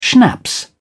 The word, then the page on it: schnapps: British English